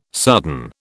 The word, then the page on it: sudden: British English